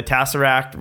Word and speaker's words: Tesseract: You might want to say it right. – That's okay. – This one's for you, Mike. Tesseract. There you go.